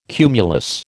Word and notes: cumulus: American English

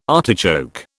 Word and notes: artichoke: British English

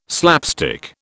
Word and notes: slapstick: British English

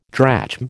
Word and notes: drachm: American English

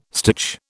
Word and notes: stitch: British English

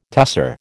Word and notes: tusser: American English

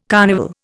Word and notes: carnival: Indian

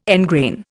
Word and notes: engrain: Indian

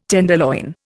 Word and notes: tenderloin: Indian

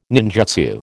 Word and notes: ninjutsu: American English

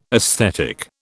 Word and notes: aesthetic: British English

